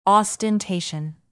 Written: ostentation

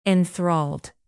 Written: enthralled

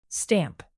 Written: stamp

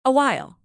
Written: awhile

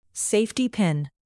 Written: safety pin

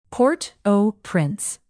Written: port-au-prince

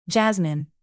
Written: jasmine

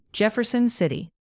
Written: jefferson city

